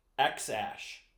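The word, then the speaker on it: X Æ: It's pronounced X Ash.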